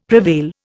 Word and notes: prevail: Indian

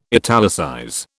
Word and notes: italicize: British English

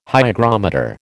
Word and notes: hygrometer: American English

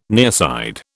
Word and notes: nearside: British English

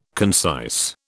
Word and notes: concise: British English

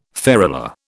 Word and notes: ferula: British English

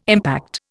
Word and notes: impact: Indian

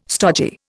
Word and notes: stodgy: Indian

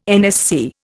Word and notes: NSC: Indian

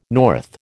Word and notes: north: American English

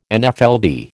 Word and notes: Nfld: American English

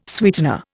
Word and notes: sweetener: Indian

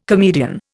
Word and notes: comedian: Indian

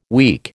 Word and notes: weak: American English

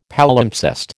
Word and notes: Palimpsest: American English

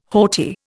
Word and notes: haughty: Indian

